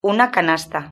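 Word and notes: una canasta: Spanish (Spain)

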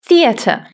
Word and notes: theatre: British English

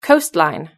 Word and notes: coastline: British English

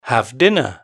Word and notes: have dinner: British English

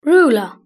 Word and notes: ruler: British English

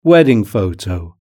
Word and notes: wedding photo: British English

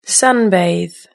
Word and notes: sunbathe: British English

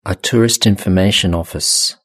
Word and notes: a tourist information office: British English

